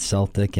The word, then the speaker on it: Celtic: I said Celtic, and they quickly corrected me. It's Celtic Thunder.